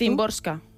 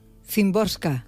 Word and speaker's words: Szymborska: A ver, ¿cómo lo pronuncias tú? – Szymborska. – Szymborska. – Entiendo yo que sí, por lo como yo he escuchado a la gente pronunciarlo. – Vale, vale. – Que me corrijan si me equivoco, por favor.